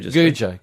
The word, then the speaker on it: Gouger: Is it Goodner or spelt Goocher or Goocher? – Goocher. – And it's spelt Gouger and all that sort of stuff.